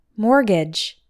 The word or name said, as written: mortgage